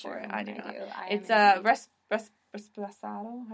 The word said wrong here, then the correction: reposado: How do you say it? – Tequila reposado. – Reposado.